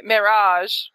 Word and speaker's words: Merhige: Merhige. Am I saying that right, Mike? – It sounded like marriage, but just like a little drawn out, at least in the interviews that I've heard with him.